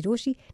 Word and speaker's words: Rochie: मेरा नाम है मिर्ची रोशी, नाम की स्पेलिंग है ROCHIE, और मेरे साथ लाइन पर अभी साहिबा है.